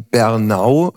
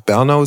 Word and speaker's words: Bernau: Du bist seit vielen Jahren, äh, seit mehr als 25 Jahren Jugendrichter in Bernau. Ich glaube, die Betonung ist immer die zweite Silbe, weil manche auch Bernau sagen, also Bernau bei Berlin.